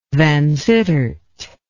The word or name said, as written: Vansittart